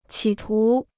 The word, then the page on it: 企图: qǐ tú